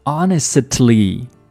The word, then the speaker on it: honestly: 它的拼写是 honestly, but 它的拼写。和它的读音在口语中是不一样的，我们不会把它读成 honestly，读起来非常的费劲，我们把那个 t 直接省略掉，读成 honestly， honestly， honestly。